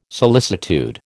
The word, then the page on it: solicitude: American English